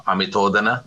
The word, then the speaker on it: Amithodhana: And that's how I met Megara Amithodana. That's how we pronounce his last name.